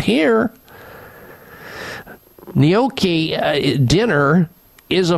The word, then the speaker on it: gnocchi: Feed your family of four gnocchi.